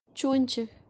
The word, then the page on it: chonchi: Kashmiri